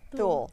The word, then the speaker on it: Thule: From, am I saying that right? Thole? – Thule.